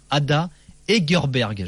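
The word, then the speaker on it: Ada Hegerberg: Juste petite parenthèse également, Kuba sur le hashtag RMC Live, je rappelle le nom du premier ballon d'or féminin, Ada Egerberg. Je l'ai mal prononcé tout à l'heure.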